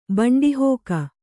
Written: ಬಂಡಿಹೋಕ

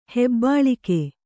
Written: ಹೆಬ್ಬಾಳಿಕೆ

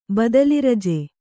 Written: ಬದಲಿರಜೆ

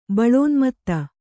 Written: ಬಲೋನ್ಮತ್ತ

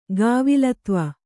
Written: ಕೇವಲತ್ವ

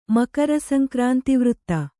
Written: ಮಕರಸಂಕ್ರಾಂತಿವೃತ್ತ

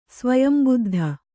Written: ಸ್ವಯಂಬುದ್ಧ

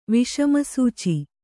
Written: ವಿಷಮಸೂಚಿ

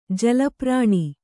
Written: ಜಲಪ್ರಾಣಿ

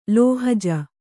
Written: ಲೋಹಜ

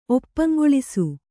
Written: ಒಪ್ಪಂಗೊಳಿಸು

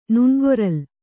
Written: ನುಣ್ಗೊರಲ್

